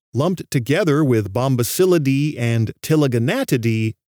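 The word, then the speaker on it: Ptiliogonatidae: The family most closely related to the waxwing family is Tilaganatidae. That's got a silent P at the beginning, Tilaganatidae.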